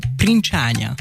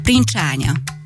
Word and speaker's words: princsánya: Igen, ó, tökéletesen kiejtetted, pedig képzeld, hogy ez olyan nehéz szó, és ritkán használom, hogy még sok roma sem tudja, tehát van, amikor ilyen mobil szóként használják roma raggal, hogy szemöldökó, nem tudják, hogy princsánya, és hogyha még el is mondod, hogy így van, nem tudják elsőre ilyen szépen kiejteni, úgyhogy nagyon gratulálok neked, ragad rád ez a nyelv igazából tényleg, úgyhogy remélem a hallgatókra is.